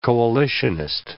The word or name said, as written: coalitionist